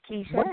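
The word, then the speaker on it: Kisha: My name is Keisha, and my question is...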